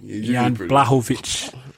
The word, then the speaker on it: Jan Blachowicz: I'm actually Jan Blachowicz. – Blahovic, okay.